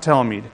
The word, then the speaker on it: Talmid: I think it's a Talmid. Is that all right? A disciple of a rabbi. I believe it's pronounced Talmid.